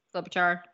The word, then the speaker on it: Klobuchar: It's basically saying if Christine Gillibrand and Klobuchar, is that how you say it? Klobuchar? – Yes, Klobuchar.